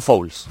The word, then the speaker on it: Fowles: El coleccionista de John Fowler, no sé si lo digo bien. John, Fowles.